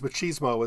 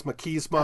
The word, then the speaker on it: machismo: Although uh, Merriam-Webster has machismo as their first pronunciation. Second is machismo. – I stand with Merriam-Webster. – It does at least sound like uh, either one is acceptable, though.